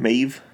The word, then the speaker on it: Maeve: Is that how you say her name? Maeve? – Yeah.